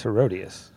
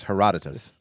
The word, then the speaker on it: Herodotus: We actually looked up the pronunciation. It's Herodotus. So Herodotus, he's known as one of the fathers of history.